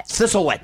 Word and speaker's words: Thistlewit: Thistlewit.